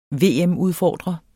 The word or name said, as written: VM-udfordrer